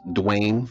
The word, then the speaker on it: Dwayne: Yes, Dwayne Fathere, D-W-A-Y-N-E, last name F-A-T-H-E-R-R-E-E.